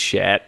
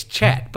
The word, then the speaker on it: chat: It's chat, dude. – No, if you're gonna pronounce it like English, it's chat. But if you're gonna pronounce it like French, it's cha.